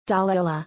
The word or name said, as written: Delaila